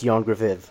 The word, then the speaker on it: Ioan Gruffudd: I just ask this question to show off that I can correctly pronounce Ioan Gruffudd.